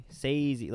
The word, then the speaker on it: Scorsese: Scorsese. Sazy, like Swayze.